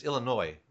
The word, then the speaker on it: Illinois: And a lot of people say Illinois, but it's Illinois. Is that right? – Yeah. It's the S is silent.